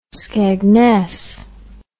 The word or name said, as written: skegness